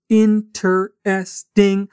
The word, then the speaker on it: interesting: in ter est ing Sim, essa palavra tem quatro sílabas interesting, mas muitas vezes na prática a gente acaba falando interesting Interesting.